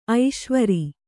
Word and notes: ಐಸಾವಿರ: aisāvira

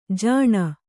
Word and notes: ಜಾನು: kannada

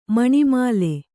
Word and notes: ಮಣಿಮಾಲೆ: kannada

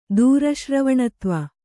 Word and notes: ದೂರಶ್ರವಣತ್ವ: Kannada

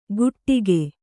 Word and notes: ಕುಟ್ಟಿಕೆ: kannada; kuṭṭike

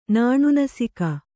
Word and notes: ನಾಣುನಸಿಕ: Kannada

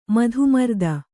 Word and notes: ಮಧುಮರ್ದನ: kannada

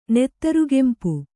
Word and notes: ನೆತ್ತರುಗೆಂಪು: kannada

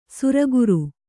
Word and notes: ಶಾರ್ಕರ: śarkara

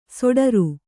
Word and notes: ಷಡಾರ: ṣaḍāra

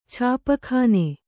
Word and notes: ಛಾಪಖಾನೆ: Kannada

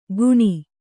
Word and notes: ಗುಣಿ: Kannada

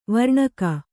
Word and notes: ವರ್ಣಕ: varṇaka

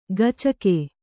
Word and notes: ಗಚಕೆ: gacake

